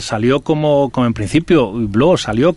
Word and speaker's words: Hublot: Yo para terminar quiero que él nos diga cómo se dice realmente Hublot, que lo decimos fatal, y como despedida de agradecimiento, cómo se dice, cómo se pronuncia. En francés evidentemente, es Hublot, pero como decía, es, ojo de buey, que es lo que creo que, en el diseño todo el mundo lo reconocerá.